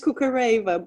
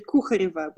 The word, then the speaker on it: Kukhareva: The English version is Kukhareva, but the Russian name would be Kukhareva, which is quite different, but probably unpronounceable.